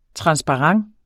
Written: transparent

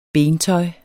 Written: bentøj

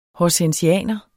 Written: horsensianer

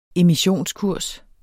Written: emissionskurs

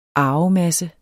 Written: arvemasse

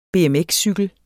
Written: BMX-cykel

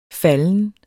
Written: falden

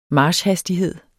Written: marchhastighed

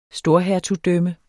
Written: storhertugdømme

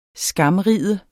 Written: SKAMRIDE

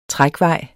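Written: trækvej

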